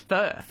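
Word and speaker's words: phở: And by the way, that is how you pronounce it. It's fur. – Fur, you'll see it written P-H-O. You've probably seen it on a Vietnamese restaurant or a cafe or something. But it's actually pronounced fur.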